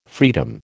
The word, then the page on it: freedom: American English